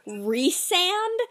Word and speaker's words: Rhysand: Like, my mind can't handle that fact, because, like, in A Court of Thorns and Roses, I know his name is pronounced Rhysand, because it's like Rhys and then and, it's supposed to be Rhysand, but I can't help but say Rhysand.